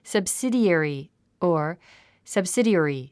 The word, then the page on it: subsidiary: səbsídièri; サブスィディエリィ